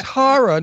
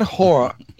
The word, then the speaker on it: horror: No, it's horror, not whore. – Yeah, and I was talking to someone last night that was saying horror. – Horror. That's how it's pronounced. not whore.